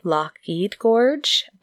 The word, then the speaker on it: Loch Ard Gorge: So I would have said Loch Ard Gorge, but I heard it pronounced by some people seem to say Loch Eid Gorge, but it's a gorge.